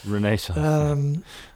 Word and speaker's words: Renaissance: you pronounce it better than me in English, but Renaissance, something like that, right? – Renaissance. – Thank you.